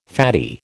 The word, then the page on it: fatty: American English